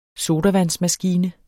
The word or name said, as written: sodavandsmaskine